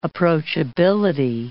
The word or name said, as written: approachability